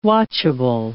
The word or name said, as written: watchable